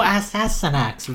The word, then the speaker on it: Astyanax: It's called the Astynax. So it's spelled A-S-T-Y-A-N-A-X. – Oh, Astynax, of course.